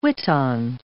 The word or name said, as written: witan